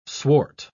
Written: swart

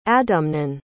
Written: adamnan

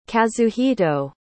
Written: kazuhito

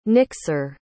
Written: nixer

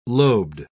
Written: lobed

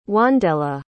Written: wandella

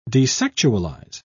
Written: desexualize

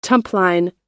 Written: tumpline

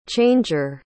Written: changer

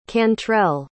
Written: cantrell

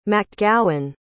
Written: macgowan

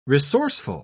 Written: resourceful

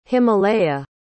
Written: himalaya

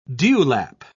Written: dewlap

